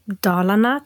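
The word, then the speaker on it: Dalarna: Aber nochmal, um, um nochmal zu Dahlana zu kommen. Mhm. Ähm, ich weiß nicht, habe ich es jetzt richtig ausgesprochen? – Ja, doch, ich glaube schon.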